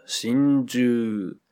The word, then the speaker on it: 心中: 心中这个词在日语里读作心中，它原本与汉语的心中一样，指心的里面，后来引申成真心实意的真心。不是有首歌叫真心英雄吗？在我心中。